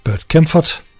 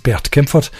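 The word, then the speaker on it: Bert Kaempfert: Melodien var Spanish Eyes, og den er skrevet af Burt Kempfert, som egentlig er tysker, som burde udtales Bert Kempfert.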